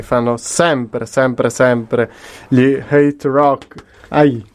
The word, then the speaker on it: HTRK: Io mi vergogno profondamente, vi ringrazio ancora una volta per stasera e adesso ci andiamo ad ascoltare una chicchetta degli Hate Rock, che si scrive ovviamente HTRK, ovvero una cosa che è un po' come siamo noi, no?